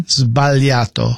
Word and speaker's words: sbagliato: How do you say mistake in Italian? – Sbagliato. – Sbagliato. Yes.